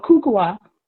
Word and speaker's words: Kukua: Is it pronounce it? The African dance that you do? – Oh, Kukua.